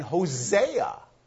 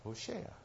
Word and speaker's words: Hoshea: And now that's also the man Hosea, which we just mispronounced because it's still too complicated for us to put the Hoshea. But that's what Hosea is, Hoshea.